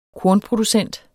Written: kornproducent

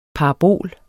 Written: parabol

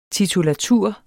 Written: TITULATURENS

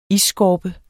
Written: isskorpe

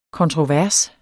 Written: kontrovers

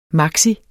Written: maxi

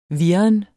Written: virren

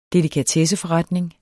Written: delikatesseforretning

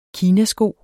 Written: kinasko

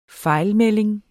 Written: fejlmelding

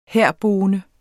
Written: herboende

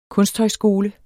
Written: kunsthøjskole

